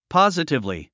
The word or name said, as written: positively